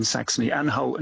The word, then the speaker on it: Saxony-Anhalt: And so the one in Saxony-Anhalt, and your pronunciation was, I think, correct, was is of course that it's former Eastern Germany.